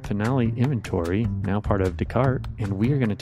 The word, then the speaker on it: Descartes: Yeah, it's Descartes. It is a Canadian firm. And so you've got that French pronunciation.